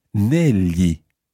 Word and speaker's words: negli: It's spelled N-E-G-L-I. So that G-L is the same GL that you hear in the word tagliatelle. So it's a very common sound in Italian, but in this word, it's pronounced negli, negli. It's like writing N-E-L-Y-I, if you like.